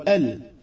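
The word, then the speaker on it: El (ال): Al-Mu'allif rahimahullah ta'ala nous fait part que El, c'est-à-dire Alif et le Lam, on dit El.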